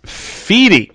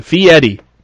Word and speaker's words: Fieri: And it's Fieri. It's Italian, so... – Yeah, something like that. – Yeah, I just looked it up. Fieri.